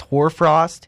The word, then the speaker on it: hoar frost: According to Bill Martin, West Coast Weather Report, It's likely in the Northern California area that you could be seeing what's called, I guess it's pronounced hoar frost, H-O-A-R, frost. It can be do or mid.